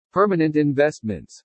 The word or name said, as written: PERMANENT INVESTMENTS